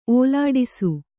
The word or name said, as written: ಓಲಾಡಿಸು